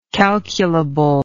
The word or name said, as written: calculably